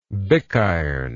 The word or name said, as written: bickiron